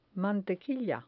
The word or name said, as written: mantequilla